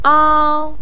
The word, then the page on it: ao: 1